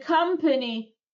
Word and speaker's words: company: The company произносится company через A, а не через O. Это не company, как многие почему-то говорят. Ну, потому что пишется через O, да? А произносится через A. Company.